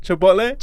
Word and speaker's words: Chipotle: Chipotle? – Chipotle.